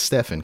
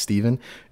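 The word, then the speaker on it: Stephan: My name is Steven, one of your hosts, spelled with a PH and an A, so you'd think it's Stefan, because phonetically, that's just how it works, but it's not. It's Steven.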